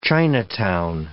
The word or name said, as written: Chinatown